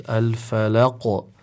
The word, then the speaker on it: الفلق: And Sheikh mentioned that because it is in the end of the verse, then we have a strong emphasis on the qalqala. So we will recite it as al-falaq. And Sheikh also mentioned that over here the qalqala will be leaning towards the dhamm when you're pronouncing it.